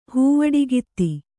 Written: ಹೂವಡಿಗಿತ್ತಿ

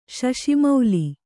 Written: ಶಶಿಮೌಲಿ

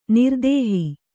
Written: ನಿರ್ದೇಹಿ

